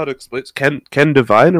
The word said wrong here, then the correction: Ken Levine: Ken Levine.